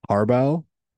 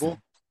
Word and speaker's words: Hrabal: Label? Is that it? – Is it H-R-A-B-A-L? – Yeah, because the H is silent.